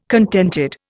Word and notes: contented: Indian

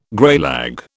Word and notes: greylag: British English

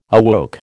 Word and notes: awoke: American English

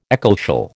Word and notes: ecclesial: American English

